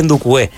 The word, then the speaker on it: Ndukwe: Pero es que Ndoukou se escribe N-D-U-K-W-E. Uh-huh. Y se dice Ndoukou.